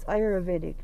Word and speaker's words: Ayurvedic: Um, and it's Ayurvedic medicine.